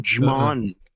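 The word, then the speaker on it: Djimon: The D is silent. There's a D in front of his name. What am I supposed to know? – Digimon.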